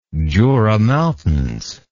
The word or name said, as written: Jura Mountains